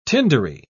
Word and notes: tindery: British English

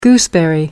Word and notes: gooseberry: American English

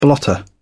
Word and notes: blotter: British English

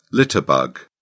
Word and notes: litterbug: British English

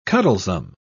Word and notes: cuddlesome: British English

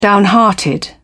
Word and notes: downhearted: British English

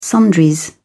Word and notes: sundries: British English

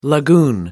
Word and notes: lagoon: American English

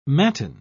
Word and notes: matin: British English

